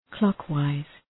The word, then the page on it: clockwise: 'klɒk,waız